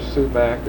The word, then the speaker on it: sumac: You ever heard of a bush or shrub around here called either a sumac or shumac? – I have. It grows in the woods, I think. – Which pronunciation would you hear? – Shumac, I believe, is what you'd hear about here.